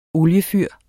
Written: oliefyr

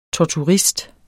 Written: torturist

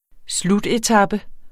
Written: slutetape

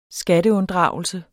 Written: skatteunddragelse

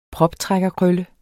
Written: proptrækkerkrølle

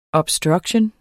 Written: obstruction

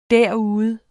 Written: derude

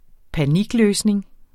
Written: panikløsning

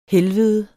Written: helvede

